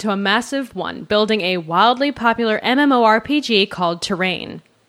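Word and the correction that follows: T'Rain: Or Train. It's spelled like T apostrophe rain.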